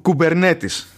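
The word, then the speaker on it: Kubernetes: και έχει καθιερωθεί ε, η ονομασία, ε, η σωστή προφορά, ξέρω εγώ, του, του, του ονόματο αυτού να είναι κου, Κουμπερνέτη.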